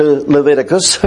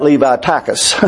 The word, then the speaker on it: Leviticus: And I must admit that when you get to Leviticus, and one brother used to call that Levi-tachus.